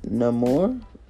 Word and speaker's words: Namor: But he's known as Namor. That's how you say his name, Namor.